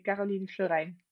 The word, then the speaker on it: Karolien Florijn: If I would say my name in Dutch, I would say Karolien Florijn.